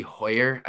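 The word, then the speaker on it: Heuer: Guys like Aaron Bummer and Cody Hoyer, I believe is how you pronounce it.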